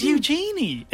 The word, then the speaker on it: Eugenie: See, it's Eugenie. It's spelt E-U-G-E-N-I-E. – Eugenie. – It's Prince Andrew's daughter. – I'm pretty sure it's Eugenie. – No, Princess Eugenie. It is.